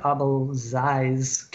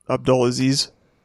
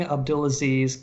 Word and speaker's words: Abdulaziz: But yeah, and so apparently, just again from this Gray Zone article, Vice published in March 2018 a video promoting the King Abdulaziz Camel Festival. I know I got that name wrong, but you can watch it. – Abdulaziz. – Yeah, the King Abdulaziz Camel Festival.